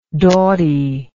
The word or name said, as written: dautie